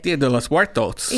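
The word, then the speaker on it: Día de los Muertos: Did I say that right? Day of the Dead Night Market. Say it right. – Dia de los Muertos. – Yeah, that sounds way better.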